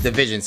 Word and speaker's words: DVSN: Oh, that's how you say Division. – Mac Miller, of course. – Did I say your name? – Yeah, it's Division. So I didn't know it was two people.